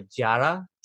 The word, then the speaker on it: Yara: I've already mentioned it once this episode, but there's a queen called Yara Sofia, and she like it's spelled Y A R A, but it's like a Jara so every time I say Yara, I want to say Jara, the Jara tribe.